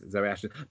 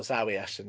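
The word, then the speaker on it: Zawe Ashton: Yes, Zoe Ashton. But it's spelled Z-A-W-E. – Oh, Zoe Ashton. Okay, cool. As in, that's how it's spelled.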